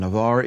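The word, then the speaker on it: Avar (עבר): And Avar is spelled Ein, Beit, Vet, Resh. Avar.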